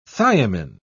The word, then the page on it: thiamin: British English